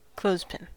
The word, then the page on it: clothespin: American English